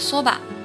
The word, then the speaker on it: そば: 日本人喜欢吃的这个荞麦面啊，就读作そば，そば，它和我们经常听到的一句歌词そばにいるよ，そばにいるよ。我会永远在你身边哦，发音呢是完全一样的，所以注意了，这里的 soba 表示的是荞麦面哦。